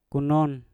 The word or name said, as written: kunon